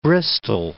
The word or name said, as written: Bristol